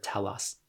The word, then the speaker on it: telos: Am I pronouncing that right? Telos? Is that the right word? – Yeah.